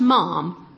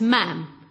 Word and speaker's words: Ma'am: And the first one is that Princess Anne must be addressed not as Mom, but as Ma'am. Brackets, yeah. Rhymes with ham.